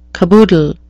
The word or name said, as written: caboodle